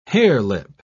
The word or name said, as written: harelip